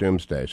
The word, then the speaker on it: Domesday: I think I've been telling my students for 20 years that it's pronounced Doomsday.